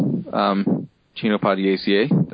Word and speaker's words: Chenopodiaceae: Chenopodiaceae. – Yeah, I usually call them chinopods. Chinopodiaceae. That's just my personal pronunciation.